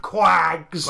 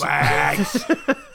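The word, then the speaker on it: QAGS: Just finished listening to episode 76, and I noticed that Quick Ass Game System, or as you pronounce it across the pond, Quags. – Quags.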